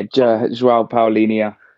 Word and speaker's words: João Paulinho: João Paulinho, have I said that correctly? – Yeah, João Paulinho.